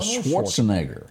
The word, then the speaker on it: Schwarzenegger: She was with Arnold Schwarzenegger. – Yep. – Did I say his name right? – That's right.